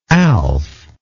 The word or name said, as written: alf